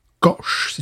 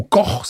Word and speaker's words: Koch: Stone Brewing Company, créée par Greg Koch si tu parles à l'anglaise ou Koch si tu parles à ah. l'allemande. ça s'écrit comment K-O-C-H.